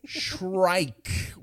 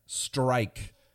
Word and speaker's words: Shrike: Of course, that's no worse than me mispronouncing Minnesota Shrike for two episodes in a row. Shrike with an S and an H. Not strike.